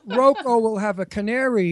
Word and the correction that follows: Roku: No, Roku.